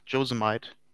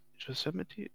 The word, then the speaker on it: Yosemite: ja, die haben einen Nationalpark, Josemite. Ja, Yosem Yosemite meinst du? Josemite. – Yosemite? Ja, genau, so rum wird es ausgesprochen.